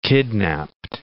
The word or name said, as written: kidnaped